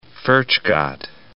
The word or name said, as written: Furchgott